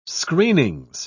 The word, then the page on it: screenings: British English